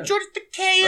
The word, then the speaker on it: George Takei: I like George Takei. Oh, yeah. George Takei is a very cool guy.